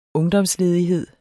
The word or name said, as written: ungdomsledighed